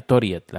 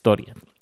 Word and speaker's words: Torget: en español Torget, pero aquí se pronuncia Toriet, la G es una I, es Toriet bueno, pues ha, estado bastante bien.